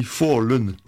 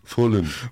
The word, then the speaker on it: Falun: Ça s'écrit F-A-L-U-N, mais en suédois, ça se dit Fallen. – Fallen.